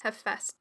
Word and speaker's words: Hephaestus: He also told us the pronunciation of the name that we weren't sure about. I think he said Hephaestus. – Yes, Hephaestus.